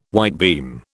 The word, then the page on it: whitebeam: British English